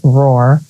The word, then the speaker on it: WRAR: It's WRAR, pronounced Roar, Inc., like a company, .com, is where you can find Kristen.